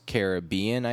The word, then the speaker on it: Caribbean: The Oxford Online Dictionaries claim that the stress on the second syllable, which is Caribbean, I think that's how that works, is the most common pronunciation in the Caribbean itself. But according to the Dictionary of Caribbean/Caribbean English usage, the most common pronunciation in Caribbean English stresses the first syllable instead.